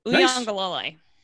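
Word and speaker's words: Uiagalelei: For one time, Uyangalole. That's how you say it. I got the pronunciation from the media guide. Uyangalole. – Nice work. I appreciate that. – So, and no, it's actually Uyangalole. There we go. it's the third syllable. It's the third syllable is where the where the stress is.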